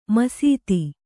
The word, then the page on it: ಮಸೆತ: maseta